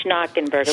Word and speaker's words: Schnockenberg: Yes, so it's Schnockenberg.